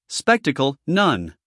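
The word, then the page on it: spectacle: uk /ˈspektək(ə)l/; en-us /ˈspektək(ə)l/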